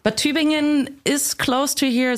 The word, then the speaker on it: Tübingen: Tübingen. – Tübingen. – It's like T and then U with two dots. – Oh, yeah, yeah, yeah. – B-I-N-G-E-N. – Okay, yeah, that's it.